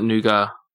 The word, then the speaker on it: nougat: Do you mean nougat? We call it nougat. – Nougat? Really? – Yeah, like the T is silent, and I'm not kidding.